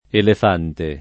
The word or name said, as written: elefante